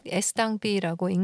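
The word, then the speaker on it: Estampes: 네, 드뷔시는 피아노 곡 판화, 에스당비라고 읽는데요. 사실 판화라는 번역보다는 뭐 영어로 하면 프린트가 맞아요.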